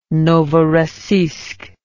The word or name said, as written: Novorossiysk